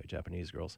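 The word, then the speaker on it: Jpnsgrls: We'll start off with a Vancouver quartet known as Japanese Girls, although they ditch a lot of vowels and it looks like Japan's Girls. But it's not pronounced that way, Japanese Girls.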